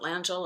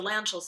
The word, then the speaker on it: Langille: Well, actually, Bernie Langille, you can pronounce it Langille or Langille, but the Langilles themselves go by Langille.